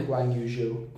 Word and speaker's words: Guanyu Zhou: Guanyu Zhou.